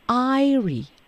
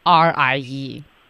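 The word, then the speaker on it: aerie: 但是人家这开头的读音呢，就变成 I 了，叫做啥词儿啊？这叫做 Airy 啊，叫做 Airy，或者呢，当然美式读法呀，也可以读成 Airy 都行。人家这词儿厉害了，还有一拼写不同的方式，叫 Eyrie，厉害吧？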